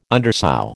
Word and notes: undersow: American English

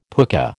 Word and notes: pooka: American English

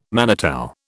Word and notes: manitou: British English

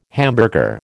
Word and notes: hamburger: American English

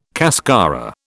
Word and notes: cascara: British English

